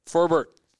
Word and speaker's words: Forbert: Forbear. Derek Forbear. – Forbert. Derek Forbert. – Forbert. Now we're getting pronunciation.